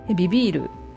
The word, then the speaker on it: vivir: ビビール VIVIR です。スペイン語の場合ね、 B も V も同じ発音でどちらもバビブベボの音でいいんですね。なので逆にバ行の音の動詞の場合はきちんとねスペリングをチェックすることが肝心です。それでは、例によって主語をくっつけてビビールの活用を言っていきます。